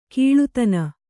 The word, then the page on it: ಕೀಳುದನಿ: kīḷudani